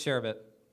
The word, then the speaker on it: sherbet: And one last one, ice cream versus sherbet. Sherbet. – Sherbet. – That's how you pronounce it. – Sherbert. – Sherbert? – It's sherbet. – You're missing an R, buddy. – There is no R.